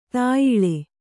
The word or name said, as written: ತೇಯೆಲೆ